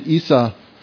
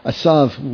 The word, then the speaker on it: Esau: And Esau, we pronounce it Esau. You know, Asav would be the Hebrew.